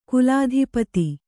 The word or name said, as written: ಕುಲಾಧಿಪತಿ